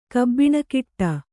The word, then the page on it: ಕಬ್ಬಿಣಕಿಟ್ಟ: kabbiṇakiṭṭa